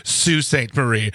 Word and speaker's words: Sault Ste. Marie: It's pronounced Sault Ste. Marie.